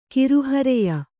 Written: ಕಿರುಹರೆಯ

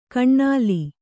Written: ಕೈನಿಲ್ಲು

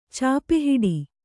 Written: ಚಾಪೆಹಿಡಿ

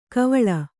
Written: ಕೈವೊಲ

